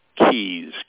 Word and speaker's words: Keyes: My last name is Keyes, K-E-Y-E-S. My first name is Ted, T-E-D.